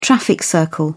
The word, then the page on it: traffic circle: British English